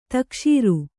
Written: ತಕಶೀರು

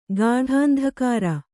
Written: ಗಾಢಾಂಧಕಾರ

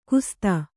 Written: ಖಸ್ಥ

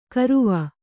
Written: ಗರುವ

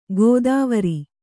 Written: ಕೆತ್ವಾರಿ

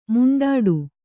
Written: ಮಿಂಡಾಡು

